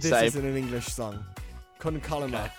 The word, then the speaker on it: Con Calma: You're calling that song Con Karma as if it's like chili con carne. – Yeah. It's how's it supposed to be pronounced? – Calma. It's an Calma. L, not an R. – Con Calma.